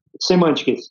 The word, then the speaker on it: Semantix: Mas para também contextualizar um pouco o nosso ouvinte aqui, e aí até peço desculpa, é assim que pronuncia mesmo? Semantics ou como é que fala o nome correto da, da empresa? – Semantics. Semantics? Isso. Fala um pouquinho da Semantics a gente.